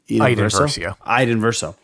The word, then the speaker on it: Eden Verso: And a little surprise like Eden Verso from the game Battlefront. Am I pronouncing that one? – Eden Verso.